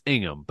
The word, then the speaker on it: Ingham: You know, they're like, obviously my last name is Ingham, but if they're like Ingram, and I'm like, no, it's Ingham. And I get to like, you know, be above them for just the tiniest little. – There's moment. No G in it. Like there's no D in Inigo Montoya. – Right. – The D is silent. – The G is silent in Ingham.